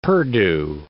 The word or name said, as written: perdue